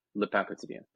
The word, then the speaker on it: Le Pain Quotidien: So I've been saying it wrong. Thing. – Me too, yeah. – You know, it is fun. People are like, the pain? What's the pain?